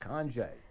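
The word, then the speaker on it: Conge: That's D-A-N-I. Among their activities is the Conje event. That's C-O-N-G-E, pronounced Conje.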